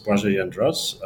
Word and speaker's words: Blazej Jedras: So my name is Blazej Jedras, and I don't hold it against you if you don't pronounce it ideally at every occasion.